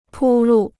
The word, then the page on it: 铺路: pū lù